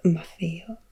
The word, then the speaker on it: Maffeo: Namely with Niccolo, his father, and Matteo, his uncle, who in the dialect of Venice was called Maffeo, so the T's were pronounced as F.